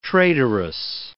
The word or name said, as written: traitoress